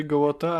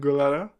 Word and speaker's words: Gołota: Będziesz, będziesz go poprawiał, że się nazywa Andrzej Gołota, a nie Andrew Golara? – Wiesz, jak to jest z nazwiskami w Stanach. Całkiem inaczej się może wymawiać, niż się pisze, czy tam...